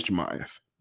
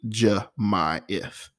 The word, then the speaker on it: Jamyeth: my name is Jemyeth. Most people don't say my name right. It's spelled J-A-M-Y-E-T-H. You know this handle is JDR Creatives. Phonetically, Jemyeth.